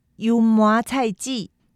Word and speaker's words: 油麻菜籽: 台湾語でユーモア菜地と読みます。